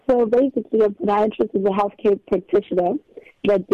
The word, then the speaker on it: podiatrist: Yeah, let's talk about what is a podiatrist. Am I even saying it correctly? – No, you're saying it perfectly. – Oh, okay. What is a podiatrist,